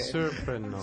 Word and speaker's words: Serpernaw: Serpernaw. – Serpernaw. – Serpernaw. – And it's French. It's in and via French Canadian.